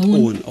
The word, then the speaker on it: Eoin: Also man schreibt in Eoin. – Ja, genau. – Also es ist bestimmt irisch oder sowas. – Ja, genau. Ich glaube, er wird Owen ausgesprochen.